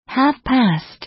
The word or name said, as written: half past